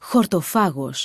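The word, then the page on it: χορτοφάγος: Greek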